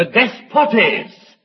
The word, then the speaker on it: despotes: It's the word which in English we pronounce thus. The despotes that bought them.